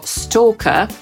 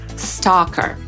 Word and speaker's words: stalker: Podsumowując, pochodzące z angielskiego słowo, które po polsku czytamy jako stalker, Brytyjczyk wymówi jako stalker, a Amerykanin stalker.